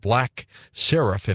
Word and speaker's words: Seraph: He's got a first major book coming out as well, an international espionage thriller called Black Seraph, if I'm pronouncing that right, Sean. Is that correct? – That's right, yeah.